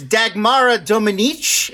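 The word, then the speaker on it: Dagmara Domińczyk: One other actor I would shout out, I believe it's Dagmara Dominic is how you would pronounce her name.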